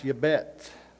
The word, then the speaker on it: Jabez: In the Hebrew, it's pronounced yabet.